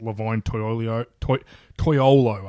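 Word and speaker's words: Levine Toilolo: Logan Paulson, the tight end, kind of replaced Levine Toyolo, I believe it's pronounced. – Yes.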